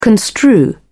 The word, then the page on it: construe: British English